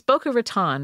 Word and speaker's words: Boca Raton: A woman named Leslie Neer in Boca del Mar heard something one day while at home, which I just thought, it's Boca Raton, right? – Yeah, Boca Raton. – Or Boca Raton. – Boca Raton. – Boca Raton. – That's how they pronounce it. It's been anglicized.